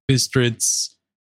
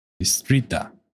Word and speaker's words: Bistrița: I don't know if a lot of people even at the time would know that Bistritz is a city in northern Transylvania, Romania, but it is according to Wikipedia. Bistrița. Perhaps. It's a more local pronunciation.